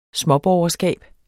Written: småborgerskab

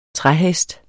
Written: træhest

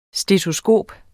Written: stetoskop

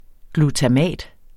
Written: GLUTAMAT

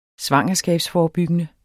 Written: svangerskabsforebyggende

